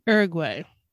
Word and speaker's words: Uruguay: Uruguay.